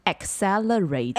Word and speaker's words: accelerate: Accelerate. – Accelerate. – Yeah, the first C is a K pronunciation, and the second C is like Acer, S pronunciation. So accelerate.